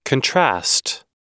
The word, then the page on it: contrast: American English